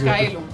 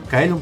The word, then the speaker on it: Caelum: De Scala ad Caelum, no sé si Caelum. – Caelum se pronuncia bien. Le pronuncié mejor que.